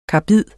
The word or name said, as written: KARBID